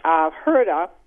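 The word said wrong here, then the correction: Gurda: I'm pronouncing Gerda. – It wrong. – Mm-hmm. – Gerda.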